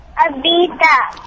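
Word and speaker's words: Abhitha: Abita.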